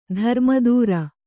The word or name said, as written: ಧರ್ಮದೂರ